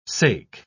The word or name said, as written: sake